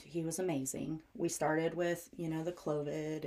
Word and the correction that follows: Clomid: and, did I say that right? – Clomid. – Clomid. Mm-hmm. That's it, mm-hmm.